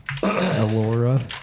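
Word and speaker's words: Elora: It's E-L-O-R-A, I think. Alora.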